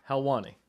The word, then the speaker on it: Helwani: Helwani.